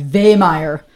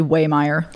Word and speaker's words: Wehmeyer: Actually, I went to Germany this past summer, and they told me, like, in Germany, I was like, how do you say my name in German, like, authentically? And we always thought it'd be like Wehmeyer, but apparently the way it's spelled, it's actually Wehmeyer.